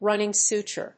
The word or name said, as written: running suture